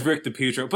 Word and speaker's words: DiPietro: And then he was like, yeah, everybody's been saying his name wrong this whole entire time. So apparently it's Rick DiPietro.